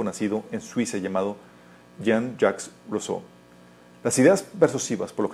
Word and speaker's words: Rousseau: Rousseau, gracias, Rousseau.